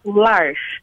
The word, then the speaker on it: L'Arche: Well, you can pronounce however you like, but I pronounce large. It's the French version of arc, the arc.